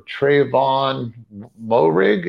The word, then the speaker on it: Tre'von Moehrig: I just wonder what it felt for Tre'von Moehrig. Is that did I pronounce that correctly? – Yeah, I think you did actually.